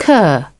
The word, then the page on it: cur: British English